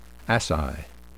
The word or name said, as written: asci